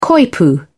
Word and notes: coypu: British English

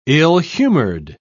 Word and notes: ill-humored: British English

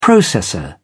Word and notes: processor: British English

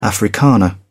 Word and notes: Afrikaner: British English

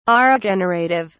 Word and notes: aregenerative: British English

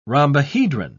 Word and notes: rhombohedron: British English

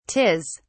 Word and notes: 'tis: British English

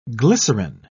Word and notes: glycerin: British English

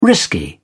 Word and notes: risky: British English

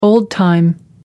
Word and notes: old-time: American English

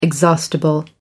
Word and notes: exhaustible: American English

